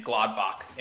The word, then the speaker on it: Gladbach: I'm going to butcher this name, Gladbach in the Bundesliga.